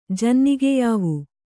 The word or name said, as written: ಜನ್ನಿಗೆಯಾವು